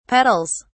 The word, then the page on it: petals: British English